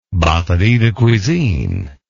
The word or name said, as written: batterie de cuisine